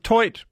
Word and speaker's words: toit: So is toit mean roof? – It's pronounced toi.